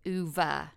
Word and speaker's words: Ove: And my favorite book this year, is A Man Called Uwe. It's spelled O-V-E. And so I always thought it was A Man Called Ove until I literally could not put the book down.